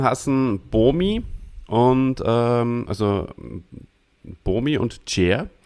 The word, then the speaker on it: Bomi: Aber bei Bomi bin ich mir sehr sicher. – Bomi ist klar, Bomi.